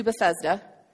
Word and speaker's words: Bethesda: head across the lake to Bethesda.